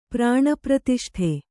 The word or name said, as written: ಪ್ರಾಣಪ್ರತಿಷ್ಠೆ